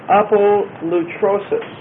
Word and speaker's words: apolutrosis: It's the word apolutrosis. Sounds like some sort of a deadly disease. But it's not quite that. Real close. Not quite. Apolutrosis. A-P-O-L-U-T-R-O, and that's a long O, and then S-S-I-S. They are down here contesting me. No, just one S. S-I-S.